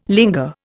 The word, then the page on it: linger: Indian